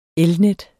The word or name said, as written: elnet